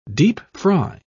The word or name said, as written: deep-fry